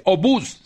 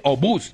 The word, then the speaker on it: obús: Dice obús, con tilde y pronunciación.